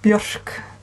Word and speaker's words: Björk: So our two most or best known musical artists in the world would be Björk and Sigur Rós, since we're pronouncing it the Icelandic way.